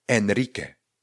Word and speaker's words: Enrique: Enrique se píše E-N-R-I-Q-U-E. E. A? Pozor, Q-U-E. Dám pryč to U, nechám tam pouze Q a E, vyslovím ke a Enry vyslovím tak, jak slyším, nebo tak, jak vlastně je napsáno Enrique.